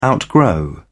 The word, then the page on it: outgrow: British English